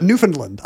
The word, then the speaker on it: Newfoundland: Newfoundland. I don't have to say Newfoundland with no D and a short A.